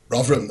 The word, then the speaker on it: Rotherham: Remember, owner of Ultraflex up there, and how do you say Rotherham? – Rotherham, that's it. – Rotherham, there we go. You got to say it correctly.